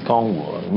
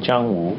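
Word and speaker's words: Zhanghu (江湖): Well, in Cantonese, Kongwu. Mandarin, Zhanghu.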